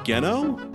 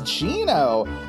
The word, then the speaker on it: Geno: Which I just watched a thing that some YouTuber talking about Mario RPG, and they called that character Geno with a hard G. That can't be right? – What? No, it's Gino.